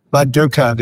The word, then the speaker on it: Bad Dürkheim: Er hat nämlich Bad Dürkheim, das wunderschöne Städtchen in der Pfalz, richtig gut ausgesprochen. Achtung. – Bad Dürkheim is serious German.